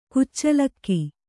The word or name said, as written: ಕಚ್ಚಲಿಗೆ